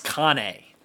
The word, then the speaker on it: Cane: I'm sure I said Canna wrong. I always read it as Mondo Cane. It's spelled like Cane. – I think it's Cane. – Cane? Mondo Cane.